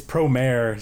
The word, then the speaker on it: Promare: It's Promare. Stop saying that. The actual word is Promare. But yeah, Promare. Well, Promare, correct pronunciation.